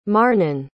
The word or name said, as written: marnin